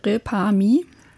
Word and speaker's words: Repami: Repami heißt das Ganze. Das heißt, das könnte... – Wie schreibt man das? – Das ist wie quasi Reparier mich, Repami.